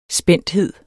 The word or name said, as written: programtype